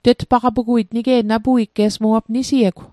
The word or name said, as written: tetpaqapuguit